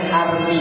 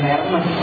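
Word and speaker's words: Hermès: Bacanya bukan begitu. Itu maksudnya salah. Maksudnya salah. Berarti gak tahu. Masih pakai barang KW berarti. Bacanya Army, bukan Hermes. Army itu bahasa, kok bahasanya tulisnya Hermes banyak? Ya itulah bahasa Perancis itu bahasa yang munafik. Tulisan sama bacanya beda.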